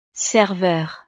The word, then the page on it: serveur: frances